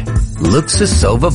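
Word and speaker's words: Luksusowa: So why is Luxusova vodka still so hard for people to say? Alright, fine. We totally understand. Polish is hard. Luxusova vodka.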